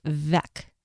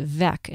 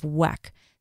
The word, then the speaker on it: Weck: So I wanted to tell you that apparently in Germany, that company name is pronounced Weck, with a V, even though it's spelled with a W. So it should be pronounced Weck instead of Weck.